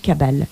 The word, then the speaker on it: Cabale: là ainsi dénommé Cabale, qui se prononce en accentuant le second A.